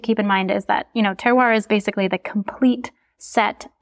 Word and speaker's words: terroir: Terroir, right? Is how it's pronounced? – Yep, yes.